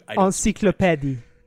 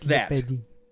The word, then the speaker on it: Encyclopédie: The pronunciation's different because it's French, but I can't do it. – Encyclopédie. – Yes, Encyclopédie. That.